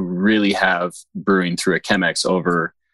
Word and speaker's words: Chemex: Is Chemex or Chemex? Is it CH or Chemex? Is it pronounced? – I believe it's pronounced Chemex.